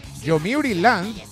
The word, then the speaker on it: Yomiuri: No, Yomiuri... Sí, lo he dicho bien. Yomiuri Land lanzó un curioso plan para sus visitantes.